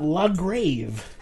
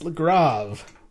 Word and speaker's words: Le Grave: I know it's pronounced Le Grave because they have a pronunciation guide for you. – It's not Le Grave? – It's not La Grave. It's Le Grave. It's the A with the umlaut over it, which in pronunciation is ah, as in father.